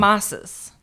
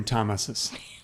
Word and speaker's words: Tomases: Because they probably spell it T-H-O-M, right? – Yeah, it sounds like plural Thomases. – Or they say wrong. – Tomases. Tomases. – Tamases. – Accent on the S's?